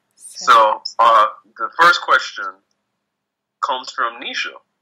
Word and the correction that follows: Naisha: She asked, wait, did I pronounce it incorrectly? – A little bit, but it's fine. Naisha.